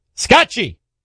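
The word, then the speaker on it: Scacchi: And Scotchy in Italian, it means chess. You know, so I don't know if that's how you pronounce it the way my grandfather would, but we say it as Scotchy.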